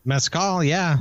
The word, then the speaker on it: mezcal: How do I say mezcal? Am I saying it right? – Mezcal, yeah.